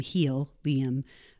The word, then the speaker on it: Healium: It's spelled H-E-A-L-I-U-M, helium, but putting it back into the individual so that they can rise higher, as if helium obviously lifts a balloon.